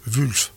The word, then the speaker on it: Wulf: Wulff. Ben oui, W-U-L-F, je ne sais pas comment vous prononcez. Très bien, voilà.